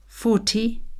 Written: forty

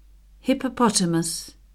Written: hippopotamus

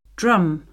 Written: drum

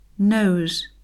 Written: nose

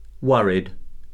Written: worried

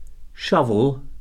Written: shovel

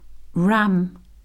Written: ram

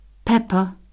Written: pepper

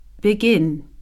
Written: begin

